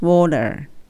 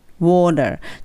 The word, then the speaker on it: water: なので water が t のところが l の発音で大丈夫です。 water water ただ気をつけるんだったらダブルの音ですね。